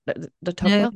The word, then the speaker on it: Tocqueville: While most Americans are aware of Alexis de Tocqueville's, did I say that wrong? De Tocqueville? – No, that's great. De Tocqueville, yes.